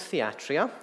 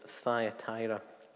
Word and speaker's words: Thyatira: So let's be clear, this is not Thyatira, as I pronounced it before, but most definitely Thyatira.